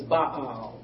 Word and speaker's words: Baal: The correct pronunciation is Baal. We've said Baal for many years, but it's Baal in the Hebrew.